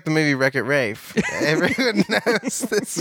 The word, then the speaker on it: Ralph: We've been told it's pronounced Rafe, which I still believe is like a Charlie Kaufman-esque.